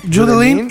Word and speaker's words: Judeline: Judeline. – Judeline y Rousseauski.